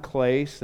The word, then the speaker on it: Clace: My name is John Clace. That last name rhymes with Grace, but it's a tough spell.